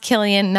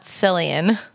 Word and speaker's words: Killian: Yeah, Randall, it's pronounced Killian, not Cillian.